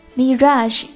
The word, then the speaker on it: mirage: Mirage M-I-R-A-G-E 海市蜃楼。英文基础比较好的朋友不难看出这个词的发音和普通的以 A-G-E 结尾的英语词发音是不同的。 Fiancé F-I-A-N-C-E 表示未婚夫，未婚妻的发音与未婚夫的发音完全相同，都是 Fiancé，但是呢，未婚妻的英文比未婚夫的英文结尾多了个字母 E。